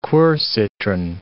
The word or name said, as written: quercitron